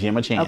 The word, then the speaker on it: Gemma Chan: Is it Gemma Chan? – It's Gemma Chan.